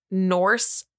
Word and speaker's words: Norse: El is in the North mythology and... It sounds like I said North. I have really bad pronunciation. Norse mythology, who is originally the name of the dead, but later came to be the goddess of death itself.